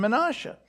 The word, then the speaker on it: Manasseh: Because the tribe of Joseph was divided up between his two sons, Ephraim and Manasseh, which is how you pronounce those names. Ephraim and Manasseh, these two sons.